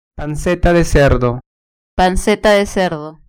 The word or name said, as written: panceta de cerdo